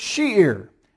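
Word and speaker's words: shir: This is the word sheer, S-H-U-W-R, but it's pronounced S-H-E-E-R, sheer.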